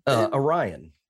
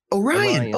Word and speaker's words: Orion: Is it Orion? – Orion. – Orion. Oh, like Orion's belt.